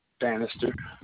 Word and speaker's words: Bannister: Bannister, B-A-N-N-I-S-T-E-R.